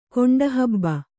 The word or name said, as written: ಗಂಟುಹುಬ್ಬು